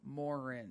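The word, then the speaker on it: moorhen: No, it's M-O-O-R-H-E-N, Morin.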